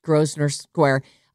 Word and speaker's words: Grosvenor Square: They say in, how do you say this word? Grosvenor Square? – Nailed it. – Okay, great. Grosvenor Square.